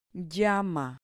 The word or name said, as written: llama